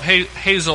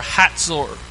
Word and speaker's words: Hatzor: And this is the tell of Hazor, or Hatzor. Usually in Israel they spell it with a T before the Z, and they pronounce it that way, Hatzor.